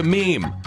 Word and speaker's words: meme: I hope my classicist friends will forgive me if I abbreviate mimim to meme. If it is any consolation, it could alternately be thought of as being related to memory or the French word meme. It should be pronounced to rhyme with cream.